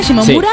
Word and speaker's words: Shimomura: De Bayonetta, que es Yuji Shimomura, ¿se pronuncia? Shimomura, sí.